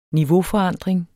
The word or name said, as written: niveauforandring